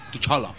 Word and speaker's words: T'Challa: Dile cómo T'Challa. Se pronuncia. – ¿Chala? – T'Challa. – T'Challa. – T'Challa. – T'Challa. – Yo estoy diciendo, ve la serie, ve la película para ver cómo se pronuncia. – Para enterarme yo de... Digo, hombre, según los dibujitos animados es T'Challa.